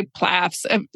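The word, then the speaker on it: PLAAFP: How do you pronounce that? – We say PLAFs. To me, PLAFs. It sounds like an AFLAT commercial. PLAF. Yeah.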